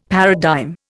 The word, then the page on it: paradigm: Indian